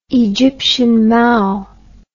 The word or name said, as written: Egyptian Mau